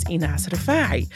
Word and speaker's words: Enas Refaei: Before moving to the UAE, I always called myself Enas Refaei because I lived in the UK and it was always really difficult to pronounce my name. So I've always been Enas Refaei, and now I'm kind of like taking ownership of my real name, which is Enas Refaei, which feels really like strange, but really like lovely.